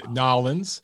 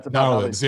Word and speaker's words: New Orleans: And I hope you have a great weekend there in Nolens. I never knew that's how they say it down there until I went there myself. – If you spell it K-N-A-R-L-I-N-S, Nolens. That's about Nolens, yeah.